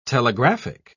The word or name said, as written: telegraphic